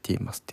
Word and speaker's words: 15MUS: あとその翌週の 4 月 5 日に、えー、ィーマス 15MUS って書いてィーマスって読む